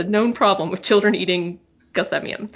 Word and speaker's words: Gelsemium: is Gelsemium sempervirens, which I say that like I took six years of Latin, because I did. Apparently, that's not how you pronounce it. I think it's actually Gelsemium, which I can't get myself to say, aside from telling the story.